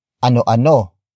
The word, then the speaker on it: ano-ano: A unique feature of the question word ano is that when asking about multiple objects, it becomes ano ano ano-ano.